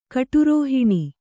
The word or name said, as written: ಕಟುರೋಹಿಣಿ